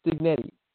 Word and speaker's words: Signetti: We had, they hired their new coach, the Elon coach, Kurt, I think it's Signotti, can't say it correctly. Did I say it? It was Signetti, Kurt Signetti.